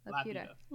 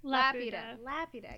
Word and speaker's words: Laputa: Laputa. – Laputa.